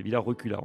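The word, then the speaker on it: Villard-Reculas: Et si je vous emmène donc à Villa Recula, alors en fait dans le reportage j'ai prononcé le S et le Z, mais pas du tout, on ne prononce pas le S, hein, c'est comme ça. Les amis savoyards ils prononcent, mais là ici dans l'Isère ils ne le prononcent pas. Donc c'est Villa Recula en fait.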